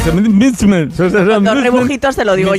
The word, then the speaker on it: msemen: Es una palabra complicada, decías M-S-E-M-E-N. Misemen, misemen, ¿vale? Y se pronuncia como lo ha hecho Hakima.